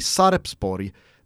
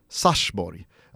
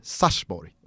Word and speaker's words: Sarpsborg: att en eh, tittare skrev liksom så här öppet till eh, Eurosport och Kanal 9-gänget att eh, varför i hela fridens namn säger ni Sarpsborg när det på norska uttalas Sarsborg, alltså med ett stumt P.